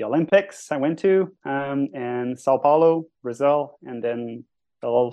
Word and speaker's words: São Paulo: You're in, I'm not going to say it right, and you're going to help me with my pronunciation, but you're in São Paulo.